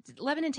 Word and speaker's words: Lebanon: It's like, you know, just a few syllables, not Lebanon as it should be pronounced. Lebanon, Tennessee, like we had a brown Walmart and like one Hardee's.